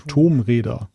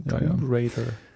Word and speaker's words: Tomb Raider: Und ich habe übrigens, da wird auch zu Recht darauf hingewiesen, ich habe mich hier als absoluter Unkenner entlarvt, weil ich allein schon den Namen auch falsch ausgesprochen hatte. Tomb Raider muss es ja dann heißen. – Tomb Raider.